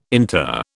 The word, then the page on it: inter: British English